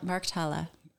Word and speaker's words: Markthalle: Then you've got the Market Hall. – Yeah. – Am I pronouncing that right? – Yeah, Markthalle. But you were close.